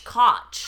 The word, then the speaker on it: Kotch: Koch. – How do you spell that? – K-O-T-C-H.